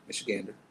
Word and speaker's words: Michigander: Oh my goodness, this Michiganer rager. – Michigander.